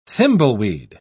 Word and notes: thimbleweed: British English

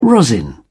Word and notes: rosin: British English

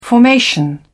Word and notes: formation: British English

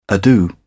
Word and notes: ado: British English

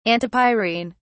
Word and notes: antipyrine: British English

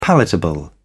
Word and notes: palatable: British English

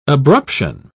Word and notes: abruption: British English

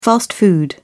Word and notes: fast food: British English

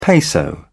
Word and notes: peso: British English

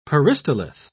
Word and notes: peristalith: British English

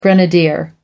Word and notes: grenadier: American English

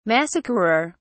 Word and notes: massacrer: British English

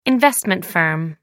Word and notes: investment firm: American English

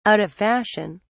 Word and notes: out of fashion: British English

